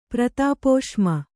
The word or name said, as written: ಪ್ರತಾಪೋಷ್ಮ